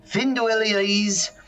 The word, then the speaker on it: Finduilies: I'll spell it for you in case you didn't know how to say it. That's an F-I-N-D-U-I. Don't want one of those. L-Y-E. God's comma. S. Finduilies. One more time. F-I-N-D-U-I. Again, don't want one of those. L-Y-E.